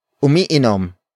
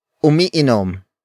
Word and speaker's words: umiinom: Word for word it's umiinom, siya, nang, tsaa, araw-araw. Umiinom, siya, nang, tsaa, araw-araw. Umiinom siya ng tsaa araw-araw. The first word is umiinom. Umiinom. Umiinom. Umiinom is spelled as U-M-I-I-N-O-M.